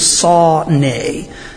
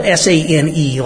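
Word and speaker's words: sa-ne (שָׂנֵא): It's the word in Hebrew, it's sa-ne. It's spelled S-A-N-E, like sane, but it's pronounced sa-ne, like a handsaw and the noise that a horse makes, sa-ne.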